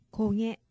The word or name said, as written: koge